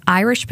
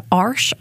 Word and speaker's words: Irish: We ate lots of potatoes, and these were white potatoes that we called Irish potatoes. Actually, my grandparents pronounced it Arsh potatoes.